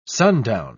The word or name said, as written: sundown